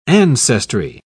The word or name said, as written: ancestry